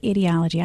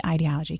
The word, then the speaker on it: ideology: You say ideology. I say ideology. Can you say it either way? – No, I think you're saying it correctly.